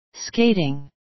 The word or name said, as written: skating